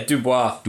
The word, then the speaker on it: Dubois: So that's Alison Dubois. Is that how you pronounce the name? – Yeah, Dubois. – Dubois. – Like Streetcar Named Desire.